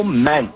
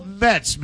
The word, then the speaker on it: Metz: Michelle Ment. – Spell that last name? – M-E-T-Z.